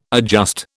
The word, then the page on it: adjust: British English